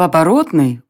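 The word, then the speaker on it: Оборотная: В оборотной, ударение на третий слог, жили преимущественно оборотни-беловолки.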